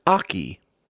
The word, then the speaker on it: oche: O-C-H-E. – O-C-H-E. – Pronounced Aki.